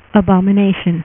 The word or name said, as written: abomination